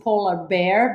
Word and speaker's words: Polar Baer: That was going to be my next question, because I saw that we do pronounce it polar bear, but the name, by the way, if you guys want to find them on Insta, it's polar B-A-E-R, correct?